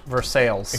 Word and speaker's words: Versailles: And the place in Indiana spelled the exact same way is pronounced Versailles.